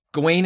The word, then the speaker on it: Gawain: Like, Arthur says Garwin. – So here's what I'll say. So Gawain is like, when I was looking up the pronunciation, that is sort of the accepted pronunciation.